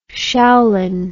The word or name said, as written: shaolin